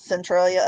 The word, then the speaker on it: Centralia: And this is in, is it Centralia, Illinois? Am I pronouncing that correctly? – Yes, Centralia, Illinois.